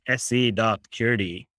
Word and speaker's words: Curity: Since it's a Swedish company, it's SE, it's the country code. So it's SE.curity.